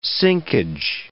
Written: sinkage

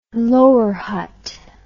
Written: Lower Hutt

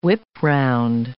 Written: whip-round

